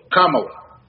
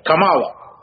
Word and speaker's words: Камала: И Камала это индийское имя. И поэтому ударение в данном случае на первом слоге Камала. Но почему-то республиканцы ее называют на арабский манер. на восточный манер. Камала.